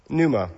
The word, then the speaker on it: pneuma: Pneuma. Yeah, pneuma. It's got often a silent P at the beginning of it.